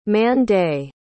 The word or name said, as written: man-day